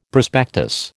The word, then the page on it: prospectus: American English